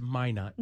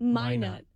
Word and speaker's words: Minot: It's Minot. – Minot.